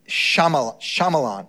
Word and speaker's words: Shyamalan: And Shyamalan, M.